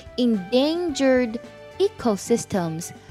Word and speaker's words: endangered ecosystems: 我们有看到这两个单词比较难读，它读作 endangered ecosystems，指的是最濒危的生态系统。